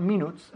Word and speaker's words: minute: Okay, se escribe minute, but we say minute. Okay, es como si estuviese escrito minute. M-I-N-I-T. Uh-huh. Minute. No se dice minute.